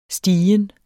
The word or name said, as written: stigen